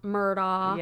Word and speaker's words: Murdaugh: And Murdaugh is phonetically spelled and pronounced Murdaugh.